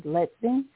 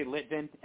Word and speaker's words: Litvin: This is our guest, Leezy the Gifted, or Leezy Litvin. Am I pronouncing your last name right? Is it Litvin? – Yeah, you can say Litvin, L-I-T-V-I-N.